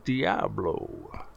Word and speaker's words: Diablo: It's it's it's pronounced Diablo. – Oh, I'm sorry. It's Diablo.